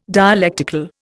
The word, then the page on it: dialectical: Indian